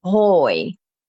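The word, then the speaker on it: hooi: And that sounds a lot like hey as well, which is hoi, hoi. So het konijn eet hoi in zijn kooi. So think cage, same sound with a K. But then it rhymes with hoi, hoi and kooi.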